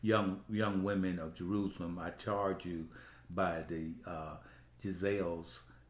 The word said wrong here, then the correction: gazelle: Did I say that right? – Gazelle. We keep saying Giselle because we had Giselle in the office. – And I was saying Giselles then. – And it's Gazelle.